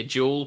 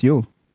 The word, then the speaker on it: Jule: That was like a Christmas porter, and all the bartenders are saying, "Yeah, the beer here Jule Porter." It was J-U-L-E, and apparently there were a bunch of like Scandinavian people in the bar who were chastising them about their pronunciation. – Yeah, no, that's Yule.